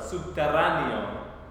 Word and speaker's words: subterráneo: Sí, la pronunciación de esta palabra es subterráneo.